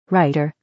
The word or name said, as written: writer